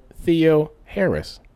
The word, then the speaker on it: Theoharis: But then the internet said Theo Harris. – Oh, is that the way it pronounces it? – That's what the internet pronounces. So we'll pronounce it like the internet.